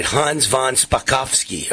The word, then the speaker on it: Hans von Spakovsky: Hans von Spakovsky.